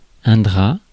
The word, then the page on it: drap: France; [dʁa]